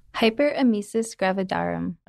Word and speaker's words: hyperemesis gravidarum: Hyperemesis gravidarum.